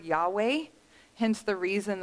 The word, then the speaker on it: Yahweh: We typically pronounce it Yahweh. Some other people pronounce it Jehovah.